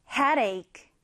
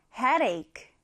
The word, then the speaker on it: headache: 자, 헤드에이크, 강세는 이 머리, 이 부분을 강하게 주시면 되고요. 같이 한번 읽어볼게요. headache. 한번 더. headache.